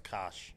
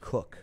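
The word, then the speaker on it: Koch: I know what it looks like. – Max Kosh. – But it's Cook. It's pronounced Cook.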